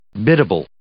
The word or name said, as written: biddable